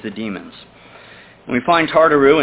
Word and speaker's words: Tartaroo: The second Greek word translated hell that we'll look at in the Bible is Tartaru. Tartaru, and it's spelled T-A-R-T-A-R-O-O.